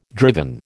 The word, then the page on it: driven: American English